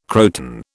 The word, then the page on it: croton: British English